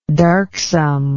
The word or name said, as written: darksomeness